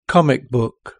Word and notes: comic book: British English

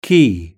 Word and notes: key: British English

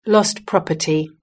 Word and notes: lost property: British English

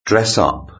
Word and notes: dress up: British English

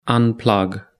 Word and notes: unplug: British English

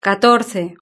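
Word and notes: catorce: Spanish (Spain)

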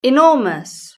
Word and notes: enormous: British English

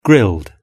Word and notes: grilled: British English

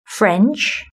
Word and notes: french: British English

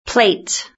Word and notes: plate: British English

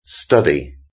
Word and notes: study: British English

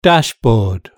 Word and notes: dashboard: British English

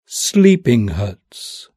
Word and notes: sleeping huts: British English

